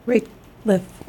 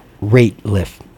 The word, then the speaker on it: Rateliff: This is by Nathaniel Rateliff. – Thank you. She helps me pronounce this last name because I struggle with Rateliff. Did I say it right? – Yeah. – Nathaniel Rateliff.